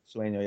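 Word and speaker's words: Tsuneo: Sueno, I believe that's how you pronounce his name. – Sueno, yeah.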